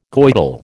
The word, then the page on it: Goidel: American English